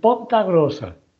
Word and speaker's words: Ponta Grossa: Y otra ya más conocida de las gentes de Baladre y de Nosotras las Personas, Alessandro de Melo, que es, él está en Ponta Grossa. ¿Lo he dicho bien? – Esto, perfecto.